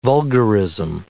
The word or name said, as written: vulgarism